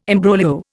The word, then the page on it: imbroglio: Indian